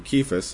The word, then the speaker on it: Cephas: Now I've heard plenty of people pronounce that Cephas, but there is no C in Greek, and this C would represent a K in the Greek, so it'd be Cephas, at least in the Greek way of saying it.